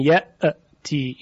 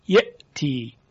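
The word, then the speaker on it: يأتي: یعنی مثلا ما نمیتونیم بگیم یأتی، این غلط از لحاظ تلفظ عربی. یأتی، یأتی، مؤمن.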